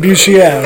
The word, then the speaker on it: Bucher: His name smells, it's pronounced Boucher. If you're like French or something.